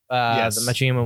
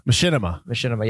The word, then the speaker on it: Machinima: And they made the Machinima one where it's like a little lighter. I mispronounced that. I know. – Machinima.